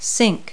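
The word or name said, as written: kitchen sink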